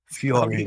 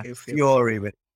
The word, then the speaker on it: Fjällräven: Fjällräven, is that right? – Fjällräven. – How do you pronounce? – Yeah, Fjällräven. Yeah.